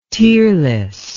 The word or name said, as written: tearless